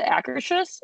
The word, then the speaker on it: Akershus: In Norway, the Akershus? Is that how you say it? – Yeah.